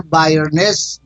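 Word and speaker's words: Byrnes: تلفظش نمیتونم چه درست بخونن چون هست B وای آر N ای e S حال بایرنس بایرنس هست که دوستان میدونن تلفظ صحیح شه من میگم برنس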